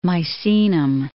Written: Misenum

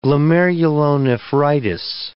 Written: glomerulonephritis

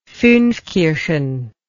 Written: funfkirchen

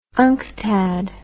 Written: unctad

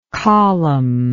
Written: columnated

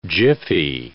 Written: jiffy

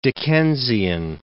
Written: Dickensian

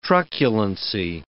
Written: truculency

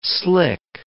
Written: slick

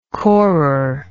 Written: corer

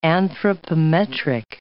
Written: anthropometric

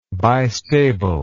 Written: bistable

